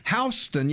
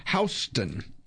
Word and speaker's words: Huston: Houston, yeah. Mm-hmm. It's pronounced just like the road in New York. Andrew W. Houston.